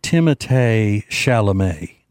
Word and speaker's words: Timothée Chalamet: His name is his parents pronounce it Timothée Chalamet.